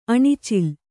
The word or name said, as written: ಅಣಿಚಿಲ್